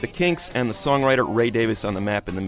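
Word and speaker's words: Ray Davies: Ray Davis, that's the way he prefers to have his name pronounced.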